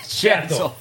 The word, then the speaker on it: Schatzl: Schatzl.